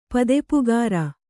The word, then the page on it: ಪದೆಪುಗಾರ: kannada